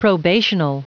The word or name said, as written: probational